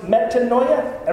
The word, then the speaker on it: metanoia: It's a Greek word that is pronounced metanoia. Everybody say metanoia.